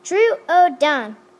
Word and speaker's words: Troodon: This dinosaur is pronounced Troodon, and is one of the most frequently mispronounced dinosaurs. Troodon actually comprises three syllables.